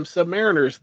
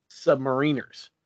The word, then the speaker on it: submariners: Any sailor who has served on the submarines, past, present, and future, consider themselves and will correct you if you pronounce them submariners. They are, in fact, submariners.